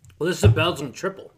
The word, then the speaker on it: Tripel: And I actually had to go to that Google site and said, how do you say T-R-I-P-E-L? – Triple. – But you say it just like you would the other version of triple.